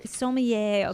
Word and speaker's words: sommelier: Sommelier. – Okay, sommelier.